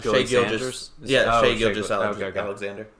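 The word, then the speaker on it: Shai Gilgeous-Alexander: Shea Gilgeous-Alexander.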